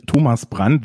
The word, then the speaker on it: Thomas Brandt: Okay, also, my name is Thomas Brandt. That's the German pronunciation.